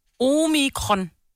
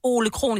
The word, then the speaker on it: Omikron: Omikron har jeg lært nu. – Kron. – Jeg sagde Ole Kron i starten, fordi ham og jeg arbejdede sammen med, det synes jeg passede bedst.